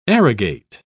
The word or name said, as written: arrogate